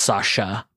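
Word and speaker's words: Sacha: It's okay. It's pronounced Sasha. Okay. I was like, when I saw the C, I was like, I've been saying that wrong.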